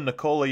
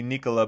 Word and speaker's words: Nikola: One of the things I like about Jokic, we all call him Nikola Jokic, which just sounds, it's like an American pronunciation of it, and it's supposed to be Nikola, but he doesn't care, and I respect that about him.